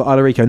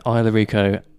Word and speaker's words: Isla Rico: And Isla Rico, I-S-L-A. – Exactly. It's like, well, it's the Spanish. – R-I-C-O. – It's not Isla, but it's spelt like Isla. – Isla, yeah. – Isla Rico.